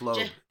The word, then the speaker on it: Loeb: Because one of the Marvel bosses, Jeff Loeb, I don't know how to pronounce it. – Yeah, Jeff Loeb.